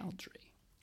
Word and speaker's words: Baldry: He also got the name John from another Bluesology member, John Baldry. Might be saying that wrong. Okay. – Baldry.